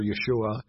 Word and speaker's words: Yeshua: how Jesus or Joshua, as we would pronounce it today, or Yeshua, as it would have been pronounced in Hebrew, means God saves.